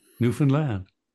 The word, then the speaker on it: Newfoundland: And Labrador comes from a Spanish word for farmer or laborer, and the accent is again on the third syllable of both. – Newfoundland.